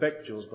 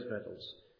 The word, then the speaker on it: victuals: I know you often pronounce that word victuals, but it is victuals.